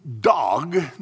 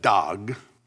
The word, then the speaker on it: Doeg: Dog, Doeg, it's spelled. Actually, in English, we would probably write D-A-A-G. So we'll go with that, Dog.